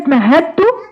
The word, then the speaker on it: مهدت: معنى كده إن الدال دخلت في التاء فبقت حرف واحد، فلا ننطق الدال ولكن ننطق الايه؟ التاء فقط. مهدت، يعني بمعنى صح ما تكتهديش عشان تطلعي الدال فتقولي مهدت، لا، الدال دخلت جوه التاء بقت حرف واحد، هي تاء.